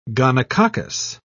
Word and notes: gonococcus: British English

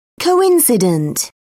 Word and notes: coincident: British English

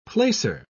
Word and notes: placer: British English

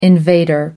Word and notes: invader: American English

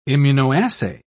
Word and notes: immunoassay: British English

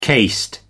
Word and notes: cased: American English

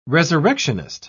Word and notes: resurrectionist: British English

